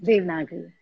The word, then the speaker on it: Devanagari: Devnagiri text. – Devanagari, yeah.